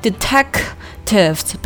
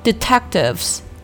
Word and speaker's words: detectives: 首先呢是前半句话，While two detectives were keeping guard at the door，注意这个 detectives 这个单词，咱们之前强调过很多遍，里面的这个 k 的音要做一个失去爆破处理，不要读成 detectives，不要给它 k 放出来，而是要做失去爆破处理，读成 detectives。